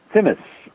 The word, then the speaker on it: tzimmes: Maureen wrote in and said, and I'm sorry about my mispronunciation here, what are mandelbrot and tzimmes, or T-Z-I-M-M-E-S? – Timis, mandelbrot and timis.